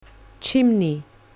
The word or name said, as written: chimney